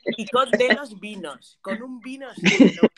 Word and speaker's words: Icod de los Vinos: Icod, ¿cómo, cómo? ¿Pero? ¿Cómo se escribe? Icod de los vinos, I-C-O-D de los vinos, vino. – Ah, de los vinos.